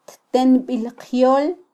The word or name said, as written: ttenbilqyol